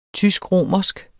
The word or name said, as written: tysk-romersk